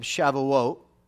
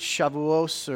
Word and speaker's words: Shavuot: You can pronounce it different ways, but we pronounce it kind of the Sephardic way here. If you're Ashkenazi, it's Shavuos or Shavuos, but it's Shavuot. You know, I pronounce it Shavuot.